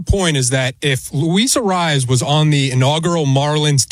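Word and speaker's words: Arráez: How do you say his last name? Arias? – Arias.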